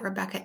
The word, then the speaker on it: Rebekah: my name is spelt a little weird compared to some Rebecca's because it's K-A-H at the end.